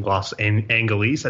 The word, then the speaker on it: Los Angeles: Los Angeles, I think is how you say it, right, Kent? – Yes.